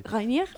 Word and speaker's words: Reinier: O sea, esta película está enfocada en Elise Reinier, ¿se pronuncia? Como si fuera un nombre francés. Reinier.